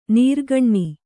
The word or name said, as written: ನೀರುಗಣ್ಣು